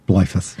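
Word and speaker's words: Bleifuss: Am I saying that right? – No, that's right, yep. – You're like, you kind of wince a little bit. It's close. It's kind of what you're telling me. How do I say it? – Blyphus. – Oh, I did say it right.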